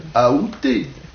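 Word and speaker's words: aoûté: La prononciation qui est considérée comme correcte, c'est la prononciation avec A. Aouté.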